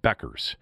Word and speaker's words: Bueckers: Okay, it's spelled Bockers, but it's pronounced Beckers or it's it's it's spelled Buckers, like B U E C K E R S, but it's pronounced Beckers. Um, would that be a German name?